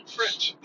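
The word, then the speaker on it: Fritch: It's F-R-I-T-C-H. – French. Yeah. – French. – It's spelled exactly the way it sounds. In English, at least.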